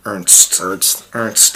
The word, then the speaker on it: Ernst: It's Ernst, right? – Ernst.